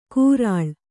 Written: ಕೂರಾಳ್

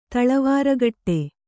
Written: ತಳವಾರಗಟ್ಟೆ

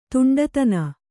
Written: ದಿಂಡುತನ

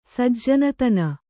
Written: ಸಜ್ಜನತನ

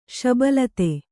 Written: ಶಬಲಿತ

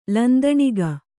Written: ಲಂದಣಿಗ

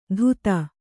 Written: ಧೂತ

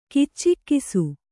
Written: ಕಿಚ್ಚಿಕ್ಕಿಸು

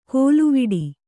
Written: ಕೋಲುವಿಡಿ

